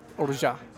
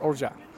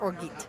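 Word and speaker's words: orgeat: Orja. – Are you sure this is how we pronounce this? – It's like Zsa Zsa Gabor, orja. – Everybody pronounces it like orgit. – Well, people pronounce things incorrectly.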